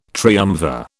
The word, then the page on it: triumvir: British English